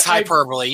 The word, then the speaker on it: hyperbole: Tenkar is a danger to all women in the gaming community, and this is not hyperbole or hyperbole. How the fuck you pronounce it? Another word that I think it's hyperbole. You get it right. You're doing all right.